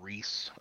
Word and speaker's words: Reese: Just search my name, Keegan Reese, R-E-E-S-E. Spelled like the candy, but I'm not as sweet.